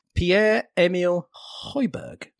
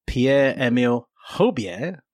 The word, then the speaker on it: Pierre-Emile Højbjerg: Pierre-Emile Højbjerg. That's my first accurate pronunciation of the man's name.